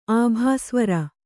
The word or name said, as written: ಆಭಾಸ್ವರ